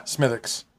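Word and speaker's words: Smithwick's: Smithix. – So. – Spelled Smithwix. – Yeah. – Pronounced Smithix.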